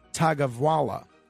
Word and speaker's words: Tagovailoa: I believe his last name is pronounced Tagovailoa.